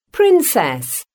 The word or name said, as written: princess